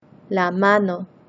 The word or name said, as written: la mano